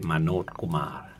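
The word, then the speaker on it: มโ น จกุม าร (मनोज कुमार): กำกับโดยมโนจกุมาร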